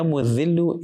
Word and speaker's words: Mu'dhillu: Ya Mu'dhillu is with a dhal. And Ya Mu'izzu, there's an Ayn there.